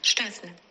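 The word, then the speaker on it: Szczęsny: Ahora se la voy a volver a Bonrichetti, a ver si sabe cómo se pronuncia Szczesny. Se lo ha consultado Oliveros a una profesora polaca. – Se pronuncia Wojciech Szczesny.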